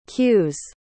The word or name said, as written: queue's